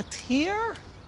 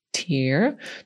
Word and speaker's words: tear: Łza to tear. To słowo zapisujemy tear. I tak samo pisze się, ale już inaczej mówi czasownik, który znaczy podrzeć albo drzeć. Tear.